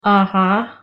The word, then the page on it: uh huh: English